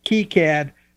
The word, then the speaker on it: KiCad: The official Wikipedia pronunciation is K-E-E, KiCad, but, you know, K-I, there's actually a little logo battle that they've got.